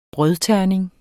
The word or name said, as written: brødterning